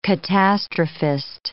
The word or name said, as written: catastrophist